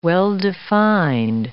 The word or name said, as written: well-defined